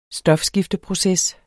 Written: stofskifteproces

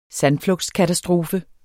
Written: sandflugtskatastrofe

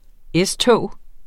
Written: S-tog

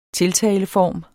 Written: tiltaleform

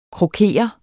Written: krokere

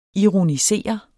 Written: ironisere

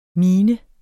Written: minens

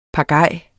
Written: PAGAJER

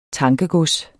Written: tankegods

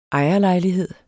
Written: ejerlejlighed

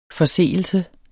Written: forseelse

